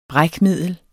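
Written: brækmiddel

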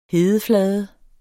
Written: hedeflade

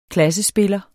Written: klassespiller

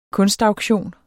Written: kunstauktion

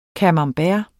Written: camembert

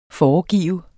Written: foregive

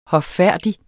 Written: hoffærdig

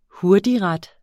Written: hurtigret

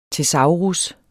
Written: TESAURUS

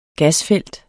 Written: GASFELT

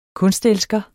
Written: kunstelsker

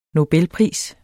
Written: nobelpris

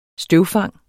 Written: støvfang

